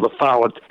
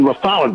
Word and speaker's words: La Follette: I've always seen him as a great hero, Robert La Follette, and I call him La Follette, not La Follette, by the way. I guess either way it can be pronounced.